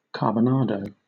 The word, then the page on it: carbonado: British English